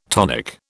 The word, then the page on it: tonic: British English